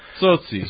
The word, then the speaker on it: Tsotsi: Tsotsi, is that how you say it? – Tsotsi.